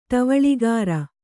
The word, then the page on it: ಟವಳಿಗಾರ: Kannada